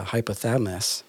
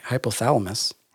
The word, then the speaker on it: hypothalamus: So when we're angry, it activates the hypothalamus. I don't know if I said that right. Hypothalamus. – Hypothalamus. Yeah, that's hypothalamus. – Correct.